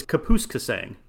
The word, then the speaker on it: Kapuskasing: So Kirk McCaskill, born in, we're going to need a pronunciation check here. – Yeah, I think that it is Kapuskasing. Kapuskasing.